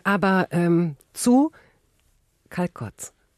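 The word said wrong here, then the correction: Calçots: Die ich jetzt aber nicht richtig ausspreche, oder? Wie werden die ausgesprochen? – Calzots.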